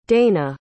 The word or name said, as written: deina